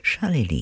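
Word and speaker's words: Charlélie: That was called Drifted, and it was from Charlely, unusual name, C-H-A-R-L-E, accent on the E, L-I-E, Charlely.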